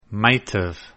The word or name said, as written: might've